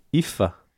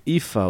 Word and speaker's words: Aoife: C'est comme Eva, mais avec un F, IFA. – Mais c'est pas le même qu'il disait. – Ifa O'Donovan. Ah ouais. il disait comme ça. Ifa. Oui, mais ça s'écrit A-O-I-F-E.